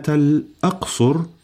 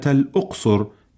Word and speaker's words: الأقصر: مستمعين الكرام، قل زرت مدينة الأقصر بفتح الهمزة، ولا تقل زرت مدينة الأقصر بضم الهمزة.